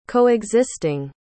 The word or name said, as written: coexisting